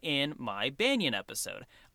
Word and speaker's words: banyan: I think I called it banyan, or something similar to that. Apparently, it is more commonly pronounced banyan, so I'm switching how I'm saying it so I sound more normal.